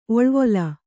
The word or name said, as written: ಒಳವಾಲ